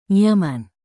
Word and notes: nyaman: Indonesian

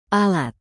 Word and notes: alat: indonesian

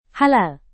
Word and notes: halal: Indonesian

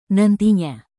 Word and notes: nantinya: Indonesian

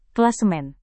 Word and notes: klasemen: Indonesian

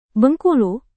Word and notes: bengkulu: indonesian

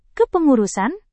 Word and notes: kepengurusan: indonesian

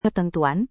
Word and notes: ketentuan: indonesian

